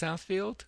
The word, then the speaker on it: Southfield: Southfield, Michigan? – That's correct.